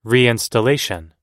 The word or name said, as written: reinstallation